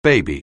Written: baby

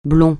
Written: blond

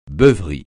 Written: beuverie

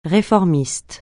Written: réformiste

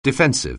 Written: defensive

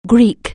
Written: Greek

